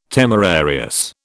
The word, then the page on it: temerarious: British English